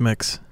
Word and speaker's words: Mx: How do I pronounce MX? Is that mix? – What is it? – Yeah, I think it's mix. – You think it's mix? Okay, so do I.